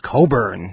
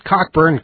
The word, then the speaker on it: Cockburn: Back in the 80s, everybody knew it was Bruce Cockburn, not Cockburn, Cockburn, because there was a whole big PR campaign to pronounce it right.